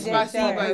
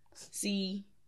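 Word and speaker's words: Spasiba (спасибо): Spasiba is how you say thanks. – Say it again. – Spasiba. – Spasiba. Spasiba. You space it out? – No, it's one word. It's one.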